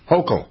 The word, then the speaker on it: Hochul: Was she a lawyer Hochul. – Before? Hochul.